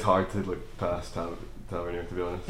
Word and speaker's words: Tavernier: Manages more crosses than Tavernier in any given game.